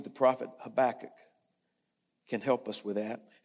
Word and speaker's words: Habakkuk: I would tell you, first of all, I'm going to tell you, that's the way I pronounce his name. You might have a different way of pronouncing it, and that's okay. I think this is as close as I'm going to get. It's Habakkuk.